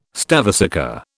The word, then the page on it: stavesacre: British English